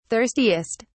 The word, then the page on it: thirstiest: British English